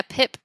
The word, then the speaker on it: PIP: And something else I was going to say as well is that you mentioned to me that you're working with, is it PIP? Is it pronounced PIP? – Yeah, PIP.